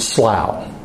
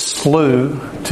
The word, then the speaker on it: Slough: And by the way, if you're a stickler for how that word is pronounced, if you're from my country, my original country, you would pronounce it Slough. But I've been away from it for so long. So it's Slough to me, and it's Slough to most American English-speaking people.